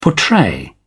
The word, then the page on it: portray: British English